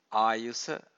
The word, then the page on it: ayusa: Pali